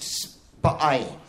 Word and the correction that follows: Spui: How? – Spow.